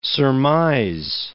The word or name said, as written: surmise